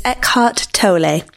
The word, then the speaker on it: Eckhart Tolle: The correct pronunciation, I now understand, is Eckhart Tolle.